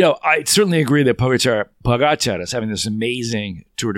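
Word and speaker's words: Pogačar: Now, someone told me today actually how to say his name. Pogacar. It's the accent on the second ga, Pogacar.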